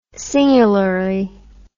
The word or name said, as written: singularly